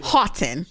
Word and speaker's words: Houghton: Is that how you pronounce his name? Yeah. Houghton.